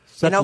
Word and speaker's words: settlor: Is it settler or settler? S-E-T-T-L-O-R. – Correct. – Settler. In Oklahoma, we're going to make at least 3 syllables out of any word.